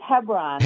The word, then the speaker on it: Hebron: And you're in Hebron. Is that how you say it? Hebron, Indiana? – I think it's Hebron, Indiana.